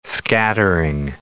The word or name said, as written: scattering